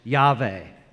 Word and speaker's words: Yahweh: The Greek tetragrammation of this word in the Old Testament is actually Yod-Heh-Vav-Heh, and most biblical scholars believe that this was probably pronounced Yahweh or Yahweh.